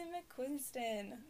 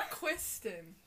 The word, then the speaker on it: McQuiston: Red, White, and Royal Blue by Casey McQuiston. – Nope. Okay. – I said it wrong! – McQuiston. – McQuiston! No N.